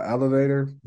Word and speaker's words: ELVTR: So probably the program is with a company called Elevator, but it's spelled ELVTR.